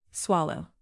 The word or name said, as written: swallow